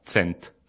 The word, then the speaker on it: cent: Et ensuite, 100, c'est très facile, ça s'écrit comme en français, C-E-N-T, sauf que ça se prononce pas tout à fait pareil, vu que le C se prononce ts en espéranto, donc ça se prononce tsent.